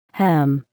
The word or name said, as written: hem